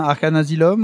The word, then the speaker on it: Arcane Asylum: Est-ce que je prononce bien Arcane Asylum ou... Exactement, à l'anglaise.